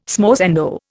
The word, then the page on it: smorzando: Indian